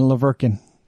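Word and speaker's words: La Verkin: I say Hurkin and Laverkin. – Because, well, that's the proper way to pronounce them.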